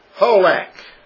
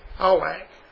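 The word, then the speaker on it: Halak (הלך): We're going to look tonight at 1980, the word in the Hebrew, and it's pronounced according to Strong's, Halak, like H-A-W-L-A-K, Halak.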